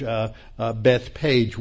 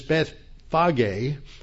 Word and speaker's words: Bethphage: So these older photographs from the early 20th century show what a small village Bethpage was. But it's still not very accurate because Bethphage, which is the Hebrew pronunciation, means the house of figs.